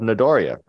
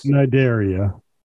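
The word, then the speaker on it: Cnidaria: Corals are under the phylum of Nidaria, right? – Yeah. – And the sea is silent. It starts with the sea. – Corals. Nidaria.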